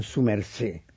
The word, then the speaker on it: su merced: le llaman su merced. Pero con acento en la E, sin la D final.